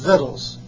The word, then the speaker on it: victuals: See, it's really, it looks like victuals. See, it comes from life. Victuals. They're pronounced victuals. I didn't ever understand. My grandma Waite in Elyria, Ohio. We used to go to her house when I was a young boy, and she used to talk about Stanley, that's her husband, give me some victuals. We'll put them out, see, that's her name for the food. See? That's what it is. But it's spelled victual, see, but it's pronounced victuals. So that's what that is, some food.